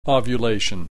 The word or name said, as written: ovulation